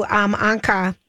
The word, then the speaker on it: Anka: getting ready to interview Anka, rhymes with Bianca, about being her ghostwriter.